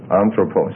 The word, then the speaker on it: anthropos: Anthropos, right. Well, the accent changes. You don't know those rules yet, but don't worry about that. The accent is on the first syllable on this, and it's anthropos. Anthropos.